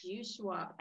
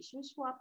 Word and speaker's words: Shuswap: Shuswap. I didn't want to pronounce it incorrectly. Shuswap Lake.